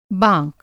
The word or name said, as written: बाँक